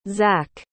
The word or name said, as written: zach